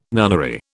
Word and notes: nunnery: British English

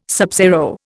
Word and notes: subzero: Indian

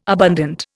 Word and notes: abundant: Indian

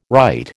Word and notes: rite: American English

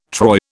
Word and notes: troy: British English

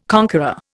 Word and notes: conqueror: Indian

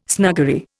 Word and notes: snuggery: Indian

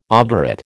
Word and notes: obdurate: American English

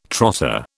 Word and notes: trotter: British English

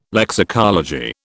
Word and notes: lexicology: British English

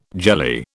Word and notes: jelly: British English

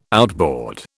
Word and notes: outboard: British English

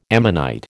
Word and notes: ammonite: American English

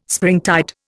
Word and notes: springtide: Indian

